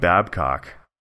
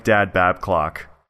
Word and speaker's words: Babcock: He once gaffed and it came out Mike Dad Babclock, and that it's on Vine somewhere.